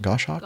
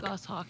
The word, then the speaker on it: Goshawk: T-45 Goshawk. Is that the way you pronounce that? Goshawk? – Goshawk, yeah.